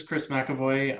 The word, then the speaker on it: Kris McEvoy: Yeah, so my name is Kris McEvoy.